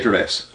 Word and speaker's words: Gervais: Yeah, Gervais.